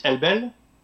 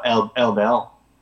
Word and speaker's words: Elbel: And it's in French it's Elbel, and in English it'd be Elbel. – Yeah. Okay. And you're fine with both versions. – Yeah, I'm fine with both versions. You can say elbow.